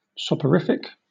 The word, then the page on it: soporific: British English